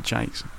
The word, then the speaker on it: Jacques: Brian Jakes. Or Jacks. I never knew how to pronounce it. – Yeah. – J-A-C-Q-U-E-S. – Yeah. – Jakes.